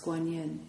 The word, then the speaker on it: Guanyin: Another place they have it is in relationship to Kanzeon, which is the Japanese way of pronouncing the word that's pronounced Guanyin in Chinese.